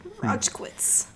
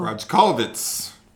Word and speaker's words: Rojcewicz: Rojkiewicz. – Rojkiewicz. – R O J C E W I C Z. – Oh. Rojkiewicz.